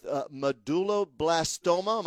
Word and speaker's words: medulloblastoma: who at 15 was diagnosed with medulloblastoma. Am I saying that right, Eddie? An aggressive form of brain cancer, correct? – Yes, sir, that's correct.